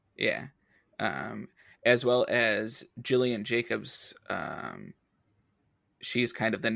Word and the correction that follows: Gillian: I'm sorry, it's Gillian? – Gillian. – The hard G.